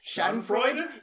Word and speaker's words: Schadenfreude: Schadenfreude? – Yeah.